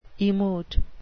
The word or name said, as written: emote